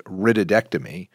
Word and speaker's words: rhytidectomy: And the proper term is rhytidectomy, which is spelled R-H-Y-T-I-D, which is a righted, which means a wrinkle, and an ectomy means it's being removed.